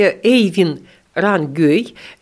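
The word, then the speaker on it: Øyvind Rangøy: В этом томе номер 6 Эйвин Рангёй, на норвежском языке его имя пишется так, что вот таких букв у нас нет ни в эстонском языке, ни в русском. Но вот так мы его решили имя, что оно, так по-русски оно должно звучать именно так.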